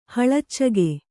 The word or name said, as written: ಹಳಚಿಗೆ